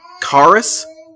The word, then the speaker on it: charis: So the Greek word, so again, I'm talking about the New Testament, and that's written in Greek mostly, so, the Greek word for grace is charis, I think is how you say it. Charis, which sounds a lot like our English word charismatic, and I think that has a double meaning there too.